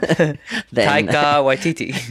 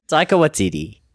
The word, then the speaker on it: Taika Waititi: Taika Waititi. – It's comedy. – Yeah. – That is like Tiger, but with a K, and then Waititi is like Waikiki. – Taika Waititi.